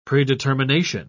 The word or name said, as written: predetermination